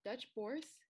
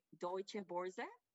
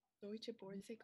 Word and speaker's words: Deutsche Börse: Dutch Bourse, is that how you say it? Dutch Bourse Group? – It's like more like Deutsche Börse. – Deutsche Börse Group? – Uh-huh. Yeah.